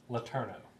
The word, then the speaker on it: Letourneau: Army and its contractors turned to a guy named Robert Gilmore Letourneau, and I hope I'm pronouncing his name right. – Sounds right to me.